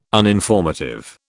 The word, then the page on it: uninformative: British English